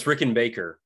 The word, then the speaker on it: Rickenbaker: Is your last name pronounced like the guitar, Rickenbacker? – No, it's Rickenbacker.